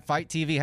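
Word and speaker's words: FITE TV: All right, Fight TV, how do you spell that? – F-I-T-E TV.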